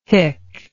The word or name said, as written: hic